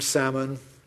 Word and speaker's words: salmon: We've got letters L that we don't pronounce in could and walk and salmon.